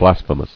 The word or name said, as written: Blasphemously